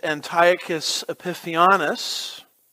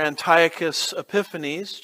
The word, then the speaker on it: Antiochus Epiphanes: It was later the empire, as Alexander the Great's empire was divided, of a man named Antiochus Epiphanes, or Antiochus Epiphanes, just depends on which syllable gets the emphasis, as we like to say.